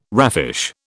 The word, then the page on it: raffish: British English